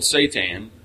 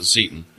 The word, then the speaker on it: seitan: There's a thing called seitan, which is, swear to God, I looked up the pronunciation. That's how they say to say it. Christine calls it seitan.